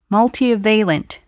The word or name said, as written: multivalent